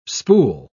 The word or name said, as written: spool